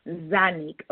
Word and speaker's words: Zonnique: and Tiny Hair's daughter, now, is her name Zonique Jean? – Zonique. – Zanik. Okay. Like Z-A, but it's spelled Z-O. Got it.